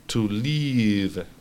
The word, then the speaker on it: leave: Hay un pequeño paralelismo de pronunciación entre el verbo vivir y el verbo partir o salir, que es to leave. Si te fijas, la pronunciación de la I, en mi caso, en la segunda vez que lo he hecho, es más largo. ¿Okay? To leave, que es partir o salir, se escribe L-E-A-V-E. En vez de L-I-V-E, L-E-A-V-E.